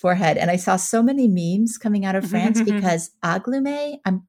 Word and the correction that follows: Angoulême: forgive me for the pronunciation. – What are you calling like, it? Angoulême. – Angoulême. Okay, thank you.